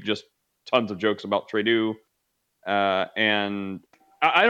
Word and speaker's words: Trudeau: Well, it's Trudeau. but that, yeah, just a pronunciation thing.